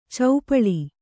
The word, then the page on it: ಚೌಪಳಿ: caupaḷi